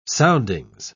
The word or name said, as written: soundings